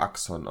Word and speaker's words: Axon: Google-Übersetzerfrau sagt mir, man spricht es einfach nur Axon aus. Die Betonung liegt auf dem A.